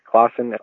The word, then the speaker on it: Clausen: Hi, my name is John Clausen. It's pronounced Clausen, almost as if there's a U after the A in Clausen, but there isn't one.